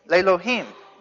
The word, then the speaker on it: Lelohim: It's pronounced in the Hebrew, Lelohim.